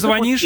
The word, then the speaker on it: звонишь: Да, а звонишь, это пока единственный возможный вариант. Не хочет наш народ признавать ударение «звонит», я имею в виду грамотную, образованную часть наших соотечественников, носителей русского языка, не хотят грамотные люди ударение «звонишь», «звонит» видеть в нормативных.